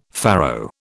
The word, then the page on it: farrow: British English